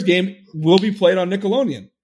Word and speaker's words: Nickelodeon: He says Nickelodeon. Nickelodeon. It's Nickelodeon. – You're dropping a D there, pal.